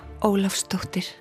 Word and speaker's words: Ólafsdóttir: Eudur Ava Ólafsdóttir.